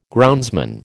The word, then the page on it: groundsman: American English